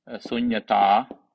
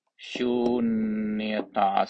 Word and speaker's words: sunyata: of which the Pali word is sunyata, or in Sanskrit, shunyata. Sunyata is you. N with tilde on top, A, T, A, long A, sunyata, or shunita, S, U, long U, N, Y, A, T, A, long A, shunita.